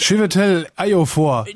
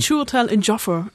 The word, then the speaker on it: Chiwetel Ejiofor: Chiwetel Ejiofor, jetzt musst du dich nicht blamieren. – Chiwetel Ejiofor, also so sage ich's.